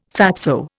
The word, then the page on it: fatso: Indian